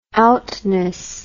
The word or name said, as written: outness